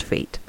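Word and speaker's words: F8: So the next album they released was Fate, which is done with a F and an 8, and it was their 8th studio album. I think it's kind of a fun, clever way of doing it, and it is pronounced Fate.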